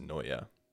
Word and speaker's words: Neue: I had one college professor who was who was very um, very uh, adamant about the pronunciation because it, everybody wants to call it Helvetica Nu, but it's spelled N U no N N I can't think N E U E or N U E U. I'm gonna write it down. – Is it a different language? Yeah, I think it's German, maybe. Okay. Uh, yeah, N E U E. Um, but but apparently it's pronounced Noya.